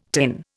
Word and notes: tin: Indian

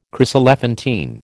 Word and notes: chryselephantine: American English

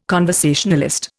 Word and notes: conversationalist: Indian

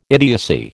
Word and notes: idiocy: American English

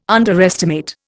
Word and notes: underestimate: Indian

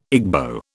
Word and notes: Igbo: British English

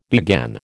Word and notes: began: American English

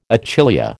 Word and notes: achillea: American English

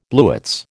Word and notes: blewits: American English

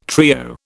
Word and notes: trio: British English

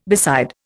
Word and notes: beside: Indian

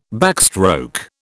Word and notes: backstroke: British English

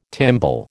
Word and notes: timbal: American English

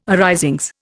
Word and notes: arisings: Indian

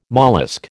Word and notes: mollusc: American English

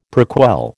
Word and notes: prequel: American English

